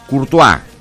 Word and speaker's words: Courtois: Se pronuncia Courtois.